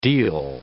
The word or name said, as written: deal